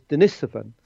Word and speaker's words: Denisova: which was Denisovan. – Hmm. What does that mean? – Well, it comes from the Denisova Cave. That's how it's pronounced.